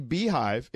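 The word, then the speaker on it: Beyhive: Oh, by the way, Dre, young lady, tweets us, it is definitely Beehive. It's spelled Bay because her name is Beyonce.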